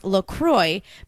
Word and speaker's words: LaCroix: Officially, it's pronounced LaCroix, but it rhymes with enjoy.